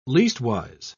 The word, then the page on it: leastwise: British English